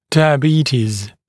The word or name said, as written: diabetes